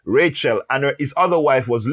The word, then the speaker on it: Rachel: Yes, her name is Rachel, not Rachel. We pronounce it Rachel in English, but the name actually is Rachel.